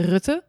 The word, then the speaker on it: Rutte: Name is Laura Schacht and the brand name is Rutte, or in Dutch we say Rutte, but the R is really hard.